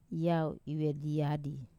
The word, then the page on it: vediadi: Wamesa